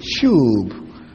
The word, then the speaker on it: shub: Word that sometimes is translated in our English Bible as repent is the word shub. S-H-U-B, shub. Some pronounce it as shub.